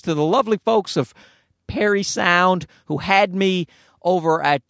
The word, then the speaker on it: Parry Sound: Now, I know I don't really think Perry sound is pronounced prairie sound, but I said it anyway. I don't know what happened. I have a trouble with it because it's spelled P-A-R-R-Y sound. So really it should be Parry sound, but they pronounce it Perry sound as far as I know.